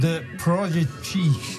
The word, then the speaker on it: The Prodigy: Firestarter van The Prodigy.